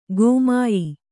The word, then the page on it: ಗಮ್ಯ: gamya